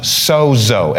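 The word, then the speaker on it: sozo (σῴζω): The word saved is pronounced sozo, S-O-Z-O, in the Greek, which is what these are transcribed from.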